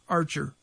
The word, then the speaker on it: Archia: It's spelled Archie, A-R-C-I-A, Archia, but everybody here called him Archer, Tom Archer.